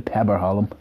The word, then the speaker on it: Peberholm: The Peberholm. Yeah, wonderful pronunciation.